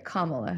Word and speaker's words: Kamala: Kamala Harris sorry, Kamala Harris. Got to make sure I start pronouncing that right.